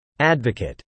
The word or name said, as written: advocate